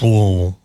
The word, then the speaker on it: Louisville: He's the one who corrected his pronunciation. It's Louisville. That's what he said. That one guy said, it's Louisville.